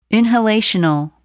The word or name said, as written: inhalational